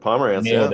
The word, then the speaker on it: Pomerantz: Pomerantz.